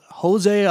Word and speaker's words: Jose: You got the featherweight champion of the world, Jose Aldo, because it's Brazilian. It's the R's they pronounce as H's, not the J's.